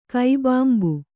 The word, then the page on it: ಕೈಬಾಂಬು: Kannada